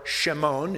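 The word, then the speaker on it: Shimon (שמעון): But we're told in verse 41 that Andrew found his own brother, Simon, or Shimon, if you will, which is the way they would have pronounced it in the Hebrew, and said to him, we have found Mashiach, or Messiah.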